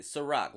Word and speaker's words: SyRok: You pronounce it SyRok. Like the vodka, but I'm older. I'm 33. I'm older than the bottle.